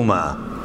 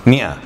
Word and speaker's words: مئة: شوف هذه لما تقول هذه، شوف من الأخطاء المنتشرة جدا ذكرنا فيها جزاه الله خير أبو سعد، هذه الألف يا أخوان لا تلفظ، حتى في ناس مذيعين أخبار وغيره كده كله ماء، ما في شيء اسمه ماء، مئة فقط، ها ماء ما في، سبع ماء ثمان ماء ما في، مئة سبعمائة تسعمائة،